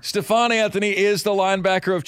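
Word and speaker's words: Stephone: S-T-E-P-H-O-N-E. I'm guessing that it's pronounced Stephon, though. Stephon Anthony, linebacker of Clemson. Any idea, Lonzo? – Yeah, it's Stephon Anthony.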